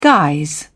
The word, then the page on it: guise: British English